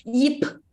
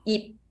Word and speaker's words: Ip: They will always pronounce the P really loud, but you don't need to, no matter there is T behind or M behind, you don't need to pronounce that loud. Just yip, yip, close your mouth.